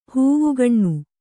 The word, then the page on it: ಹೂವುಗಣ್ಣು: hūvugaṇṇu